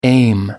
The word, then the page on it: aim: American English